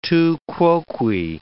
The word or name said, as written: tu quoque